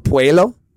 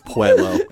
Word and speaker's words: Pueblo: And by the way, I believe it's pronounced Pueblo. – It's not. – Pueblo. Fuck you. – Pueblo. That comes from somebody from Pueblo.